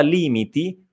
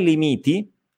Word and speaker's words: limiti: Attenzione alla parola limiti, non è limiti, è limiti, limiti. Quindi la vocale tonica, l'accento cade sulla prima I, l'accento tonico, no? Quello, della, quello sonoro, ok? Quindi non limiti, ma limiti, limiti.